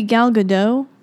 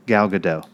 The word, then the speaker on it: Gal Gadot: I think it might be Gal Gadot. – Gal Gadot. That would make more sense.